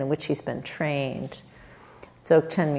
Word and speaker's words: Dzogchen: So the practice comes out of a lineage in this Tibetan tradition called Dzogchen, and Dzogchen means, it's spelled like D-Z-O-G, and then Chen, C-H-E-N, Dzogchen.